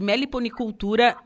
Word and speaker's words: meliponicultura: Sim, nós vamos falar sobre o quarto encontro catarinense de meliponicultura zootécnica.